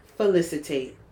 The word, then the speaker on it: felicitate: Felicitate. – And not facilitate. – No. – But felicitate. – Felicity with a tate.